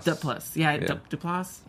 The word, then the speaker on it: Duplass: Duplass.